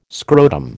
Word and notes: scrotum: American English

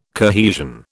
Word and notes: cohesion: British English

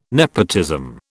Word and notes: nepotism: British English

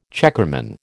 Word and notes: checkerman: American English

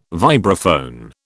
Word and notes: vibraphone: British English

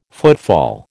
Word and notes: footfall: American English

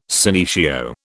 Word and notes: senecio: British English

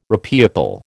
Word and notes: repeople: American English

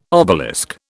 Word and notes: obelisk: British English

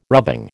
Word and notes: rubbing: American English

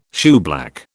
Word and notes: shoeblack: British English

